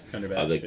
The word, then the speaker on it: Cumberbatch: Cumberbatch, The casting of him.